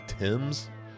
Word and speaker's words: Thames: a kid named Mason Thames, I think is how you would pronounce that, like the river. That's how it's spelled.